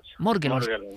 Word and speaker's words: Morgellons: ¿Cómo se dice esto, realmente? ¿Morgellons o Morgellons? – Morgellons. – ¿Podríamos definirlo? – Es una palabra inglesa, claro.